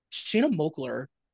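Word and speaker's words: Shanna Moakler: So they don't talk about this on the show because it's like awkward, but a week or so ago, Shayna Mokler. Whoa, I just said her name weird. – You said it right, though.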